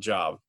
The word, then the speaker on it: Job: J-O-B, it's spelled Job, but it's pronounced Job.